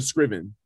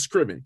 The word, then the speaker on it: Scriven: Well, no S on the end of the Scriven, but so it's just Scriven, KJ Scriven, but close, bro.